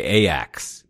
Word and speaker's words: Ajax: We would call it Ajax, but they would say Ajax. They don't pronounce Ajax. J's over here.